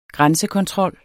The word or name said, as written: grænsekontrol